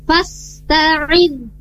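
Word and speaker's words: fasta'id (فاستعذ): Perbaikan di huruf sinnya, di sana perlu perbaikannya di kejelasan di huruf sin. Tidak fasta'id, tapi fasta'id. Nah, jelas ya pengucapannya huruf sin. Setelah itu baru pindah huruf ta-nya, fasta. Jadi enggak fasta'id, fasta'id. Jadi fasta'id billah.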